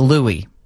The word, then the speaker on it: Louis: Even though it's spelled Louis, we're told it's pronounced Louis.